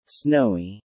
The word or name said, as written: snowy